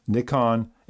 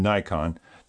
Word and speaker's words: Nikon: So, first of all, Nikon itself is said basically two different ways, Nikon and Nikon, depending on where you reside.